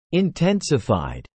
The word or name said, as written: intensified